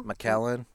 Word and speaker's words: Macallan: We had drinks with a guy the other night who was drinking, I don't even know how to say it, Macallan? It's a single. – Macallan.